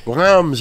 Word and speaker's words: Reims: Reims, that's how you say it, forgive me.